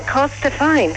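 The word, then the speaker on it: Corstorphine: I'll spell it C-O-R-S-T-I-P-H-I-N-E, Costa Fine.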